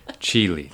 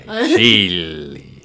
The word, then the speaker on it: Cilli: Cilli. That's right. Cilli.